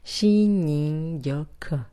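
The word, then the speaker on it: Shinrin Yoku (森林浴): Yo no controlo el japonés, pero sé que Shinrin Yoku, que lo estoy diciendo así, en realidad ellos lo cantan Shinrin Yoku.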